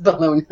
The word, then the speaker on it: Bellona: Bellona, I know. I'm probably saying it wrong. It's B-E-L-L-O-N-A. – No, Bellona is right. – O-S-C-A-R.